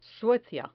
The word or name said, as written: suecia